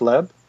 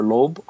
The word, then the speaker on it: Loeb: And then Jeff Leb, or Loeb, I don't know how to pronounce his name. – Yeah, I think it's Loeb. – He's like the head of TV, right? – Right. – For Marvel. L-E-B, yeah.